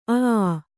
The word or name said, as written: ಅ ಆ